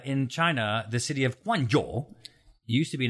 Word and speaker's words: Guangzhou: Guangzhou. – Wow. I'm working on that one. – Guangzhou.